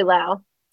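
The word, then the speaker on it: Lough: I don't, L-O-U-G-H. I don't know how to pronounce it. – That's probably Lau. – Probably Lau. – Like plow.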